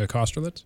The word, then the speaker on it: Kosterlitz: Uh, Kosterlitz? – Yes, okay.